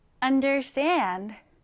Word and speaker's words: understand: 읽을 때는 understand 뒤에 이렇게 강세를 주시면 됩니다.